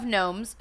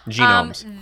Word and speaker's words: gnome: The legend of gnomes who once brewed in this mysterious region provides the inspiration for this eccentric bottle conditioned double fermented ale. – It's pronounced gnome. – Gnomes. Oh, I'm sorry. – Genomes.